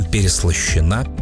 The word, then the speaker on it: переслащена: Я сразу полез проверить себя на ударение. Все верно, переслащен. Если есть «ё», она ударная. А вот в женском и среднем роде нет «ё». Поэтому там «переслащена» и «переслащено».